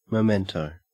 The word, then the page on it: memento: Australian English